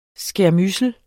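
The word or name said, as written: SKÆRMYDSELS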